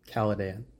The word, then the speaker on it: Caladan: Caladan. – Caladan. – That's how they say it in the movie, at least.